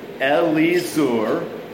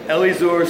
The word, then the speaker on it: Elizur: But for weird names, always pronounce the I as an it or an E, pronounce every vowel, and what you'll get is Elizur, son of Shedeur.